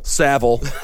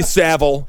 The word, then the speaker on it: Savile: Savile. Okay, Jimmy Savile.